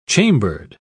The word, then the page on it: chambered: British English